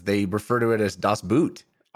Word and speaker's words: Das Boot: It's Das Boot.